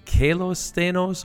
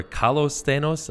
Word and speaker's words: καλος σθενος: So when we're talking about the mural that we have that is Greek, in the Greek, I don't want to butcher it, but it's kalos sthenos or kalos sthenos. I've heard it pronounced both ways.